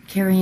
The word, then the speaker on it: Kari: Carrie Ann Swart, it's spelled K-A-R-I.